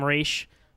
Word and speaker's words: Ra's al Ghul: Now, how do you say Ra's al Ghul? Do you say Ra's or Ra's? What's your school of Ra's al Ghul? – Denny O'Neill, creator of the character, says Ra's, so that's what I say.